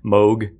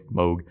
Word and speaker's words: Moog: Moog. I totally thought it was Moog. – One time, I know, because it looks like Moog. M-O-O-G. And I saw one time in parentheses they wrote Vogue, and that's always stuck in there.